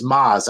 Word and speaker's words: Mads: I think it's Maz. I think like the D is silent.